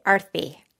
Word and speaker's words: Aarti: Almost perfect. Like a T-H, Sanskrit T, Aarti.